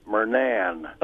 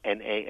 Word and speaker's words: Murnane: And the correct pronunciation of a name that is spelled Marnane or Murnane is Murnan or Murnane.